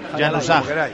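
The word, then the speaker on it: Januzaj: Yanusay. Mi vecino del primero, que es albanés, los papás de este chico también, dice que se pronuncia Yanusa, pero bueno, tenía razón, Yanusá. En España, Yanusá.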